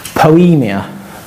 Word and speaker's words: poiema: And the translation for our handiwork or workmanship comes from a word which is pronounced poemia. Poemia. P-O-I-E-M-A. Poemia.